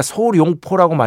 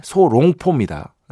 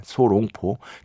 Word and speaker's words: 소롱포: 우리가 소룡포라고 많이 하는데, 소롱포입니다. 예, 소롱포. 대나무 롱자입니다. 예, 위에 보시면 대나무 변이 있어요. 그 용용자가 아니에요. 대나무 롱자기 때문에 정확한 발음은 소롱포입니다. 소롱포.